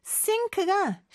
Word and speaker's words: sink: sink가 싱크대를 뜻합니다. sink. 근데 방금 제 발음에서 차이를 느끼셨죠? sink하고 싱크대. S하고 시옷의 발음에 차이가 있어요. 그래서 sink, S 발음은 좀더입 앞에서 나는 소리라는 거, 요것도 기억해 주세요.